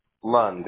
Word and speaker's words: Lund: Lund, L-U-N-D. – L-U-N-D. And Sean, do you spell that S-E-A-N? – S-E-A-N.